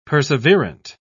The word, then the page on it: perseverant: British English